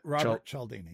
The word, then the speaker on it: Cialdini: Robert Cialdini, yeah.